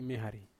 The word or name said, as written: mihari